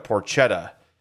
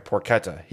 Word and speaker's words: porchetta: And then Hope got the porchetta sandwich. And I'm sorry, porchetta. He actually, JP corrected both of us when we said porchetta. And he's like, porchetta? Like, yes, that's what we said.